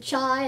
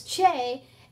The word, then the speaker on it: chai: And there's a, a, a number in Hebrew that is, is it's pronounced, some people pronounce it chai and some say it's che.